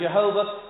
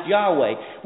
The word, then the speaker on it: Yahweh: Some people pronounce it Jehovah. It's more likely pronounced Yahweh, which means I am what I am, the self-sustaining one, the self-existent one.